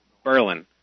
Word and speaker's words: Berlin: Berlin, New Hampshire. It's not Berlin, it's Berlin.